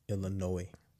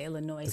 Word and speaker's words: Illinois: Y'all realize that S in Illinois is silent? What? – Illinois. – Yeah, it's silent, right? – He said Illinois with the S.